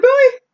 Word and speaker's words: Bowie: We went to a live taping, and they were going over some Alamo history, and they immediately got Jim Bowie's name wrong. They kept calling him Jim Bowie. And I'm like, David Bowie? Like, it's Bowie. It's Jim Bowie. Because we have the Bowie knife.